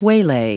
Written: waylay

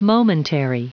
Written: momentary

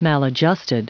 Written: maladjusted

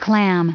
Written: clam

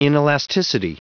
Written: inelasticity

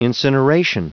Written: incineration

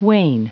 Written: wane

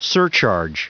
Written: surcharge

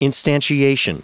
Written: instantiation

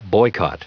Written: boycott

